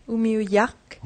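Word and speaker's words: Umiujaq: Si on lit ce mot-là, là, est-ce que ça se prononce vraiment Umiujak? – Umiujak.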